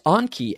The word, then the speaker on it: Anki: There's Anki, A-N-K-I. – Oh, Anki. Oh, you pronounced it Anki. Sorry. You're in fact talking about the one I'm thinking about, except yeah. I pronounced it Anki.